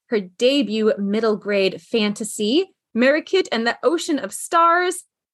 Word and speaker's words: Marikit: Oh, it is Marikit. – Oh, you say it in a really beautiful way. Marikit. Oh. There's like a roll there.